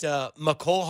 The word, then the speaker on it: Mecole: I know they talked about Mecole Hardman. We know that's how you say his name because our very own Brandon Kiley did a one-on-one with him, and that's how he told him to say his name.